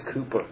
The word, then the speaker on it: Cowper: It's spelled C-O-W-P-E-R, but it is pronounced Cooper.